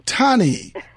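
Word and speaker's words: Tawni: Tani.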